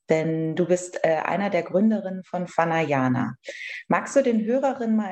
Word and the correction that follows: Fanajana: Vanayana äh, wird eigentlich Fanasana ausgesprochen ah.